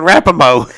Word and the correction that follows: Rapinoe: Rapaho. Rapinoe. Oh, Rapinoe. Yes.